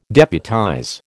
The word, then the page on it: deputize: American English